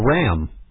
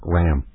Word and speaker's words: Ra: And someone called up and said it's actually pronounced Ra, not Ray. But I beg to differ. See, and it doesn't matter if it's Ra or Ray to me, as long as we're both on the same wavelength and communicating the same and understand the same exact thing. But here is my case for the pronunciation of Ray, the sun god. We have Ray, the sun god, because we get sun rays from that.